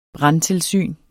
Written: brandtilsyn

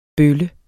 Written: bølle

